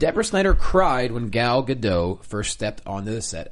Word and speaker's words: Gadot: That's French, and she's from Israel. – Good. So if you pronounce it escargot, then you can say Gadot.